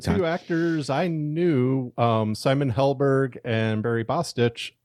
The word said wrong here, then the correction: Bostwick: Bostwick. – Bostwick, yes. – It was his birthday the other day. And you said his name wrong.